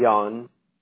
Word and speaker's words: Yan: And I'm saying your name correct, D-I-O-N? – Y-A-N, Yan.